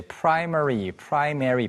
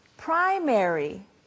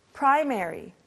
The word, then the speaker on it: primary: Repeat after me, starting with primary. Primary. Primary. Or as Stephanie said, primary. They're both okay.